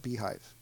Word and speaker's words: bhyve: They call it Beehive, and it's Bhyve. That's how they spell it.